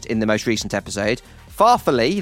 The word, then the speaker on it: farfalle: Producer Chris has also written me a pronunciation guide for farfalle. Apparently, it's pronounced farfalle. And Chris has written, emphasis on the first syllable, same pattern as totally. Farfalle, totally. Look, I don't.